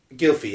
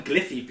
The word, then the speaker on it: Gylfi: Is it pronounced Gylfi? – Gylfi, yeah. – That's really weird. – Yeah. No, I always call him Gliffy because I just can't say Gilfy.